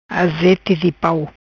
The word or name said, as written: Azete di Pau